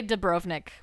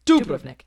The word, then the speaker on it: Dubrovnik: People say Dubrovnik, but it's Dubrovnik and Split. Yeah. – And the accent is on the first syllable.